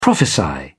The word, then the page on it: prophesy: British English